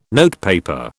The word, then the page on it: notepaper: British English